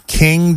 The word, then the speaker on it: Qing: Q-I-N-G. Is that how you pronounce it? – I believe so.